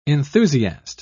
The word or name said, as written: enthusiast